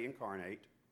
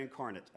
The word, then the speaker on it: incarnate: But this time, Jesus Christ, the incarnate, that's right, I say incarnate. Other people say incarnate, I think. I'm sorry, I say it like we say it in Richmond County.